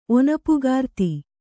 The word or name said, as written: ಒನಪುಗಾರ್ತಿ